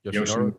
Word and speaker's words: Yoshinori: Yoshinori.